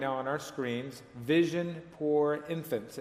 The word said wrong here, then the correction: Vision Pour Enfants: But I didn't pronounce it correctly, so let me hear you say it again. – Vision Pour Enfants.